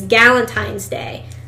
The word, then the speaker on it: Galentine's Day: And what, you call it Galent, like G-A-L? – Right. So Valentine's Day spelled with a G.